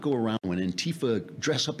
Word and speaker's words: Antifa: this thing with Antifa. Now we got to put the accent on the T. It's on the wrong syllable.